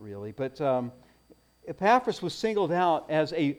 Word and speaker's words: Epaphras: But this guy, his name was Epaphras, or I don't know how you pronounce it. I pronounce it Epaphras. And therefore, since I'm the preacher, I'm right.